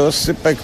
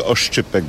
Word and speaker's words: oscypek: Ale Oscypek trochę będzie trudniejszy w wymawianiu może. Niektórzy Oszczypek będą mówili na przykład.